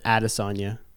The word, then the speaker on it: Adesanya: And apparently everyone's been saying Israel Adesanya's name wrong. – And how are you supposed to say it? – Exactly like I said it. Adesanya. – Okay, so Adesanya to what? – Adesanya. – Adesanya. Eh, that's close enough.